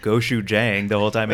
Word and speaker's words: gochujang: It's going to be gochujang, right? – I'm so proud of you right now. Oh, you said it right.